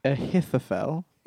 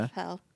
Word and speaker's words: Ahithophel: Ahithophel. There's a TH and a PH in there. Ahithophel.